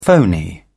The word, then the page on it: phoney: British English